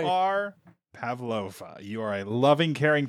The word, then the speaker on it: Pavlova: Pavlova. – Pavlova, yeah.